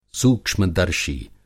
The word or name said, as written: सूक्ष्मदर्शी